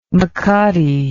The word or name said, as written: mecate